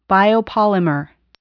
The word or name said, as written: biopolymer